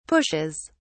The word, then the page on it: pushes: British English